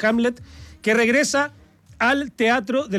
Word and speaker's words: Hamlet: Ahora, Hamlet, porque no lo dije mal.